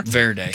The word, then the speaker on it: Cape Verde: Cape Verde.